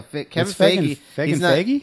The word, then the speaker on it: Feige: It's like Feige. – Feige? I thought it was Feige. – No, Kevin Feige? No, Kevin Feige.